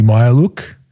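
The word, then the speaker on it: Mykhailiuk: It's Mihailuk. Svi Mihailuk, fuck that one up. So there's a real pronunciation, Mihailuk, and Malachi.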